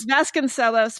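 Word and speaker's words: Vasconcellos: Yeah, I think it's pronounced Vasconcelos, but I'm legendarily bad at pronunciation. So well, I'm just... – Okay. As a musician, I'm going to say cellos. So Vasconcelos, Vasconcelos.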